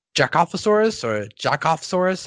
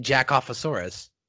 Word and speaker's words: Jakovasaurs: As we mentioned, Jakovasaurs, how are we pronouncing this? – Jakovasaur. – Jakovasaur. But I like saying Jakovasaurus. – But it's not a saurus.